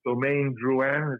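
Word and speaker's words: Domaine Drouhin: I mean, I'm an admirer, and I heard there was an opportunity to buy a property right next to the wonderful, if I hope I can pronounce it right, it's Domaine Drouin. Is that how you pronounce it? Mm-hmm. Yes.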